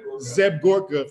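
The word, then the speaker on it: Seb Gorka: Geb Zorka, how you say his name? Zeb Gorka followed me the other day.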